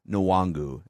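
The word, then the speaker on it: Nwangwu: So whoever last week, whoever the broadcaster was last week was saying Nwangu, and somebody else had a pronunciation calling him Kenny. It's Kane Nwangu is how you pronounce it.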